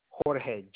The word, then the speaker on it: Jorge: You guys call me Jorge, J-O-R-G-E. – J-O-R-G-E? – Yes, ma'am. – Last name? – E-C-H-E-G-A-R-A-Y.